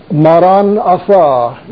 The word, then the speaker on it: Maranatha: Maranatha is how it's pronounced.